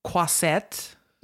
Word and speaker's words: Croisette: What was the vibe at the Croisset? Mm-hmm. Am I saying it right? – Yeah.